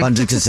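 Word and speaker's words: Bündchen: Bundchen, that's exactly how you say it, mm-hmm.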